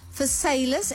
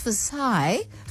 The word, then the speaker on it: Versailles: And when I was born in Wellington, 21, Versailles, and I didn't know until I went to France to go and get an award there, that they said, it's Versailles. That I realized we pronounce it incorrectly.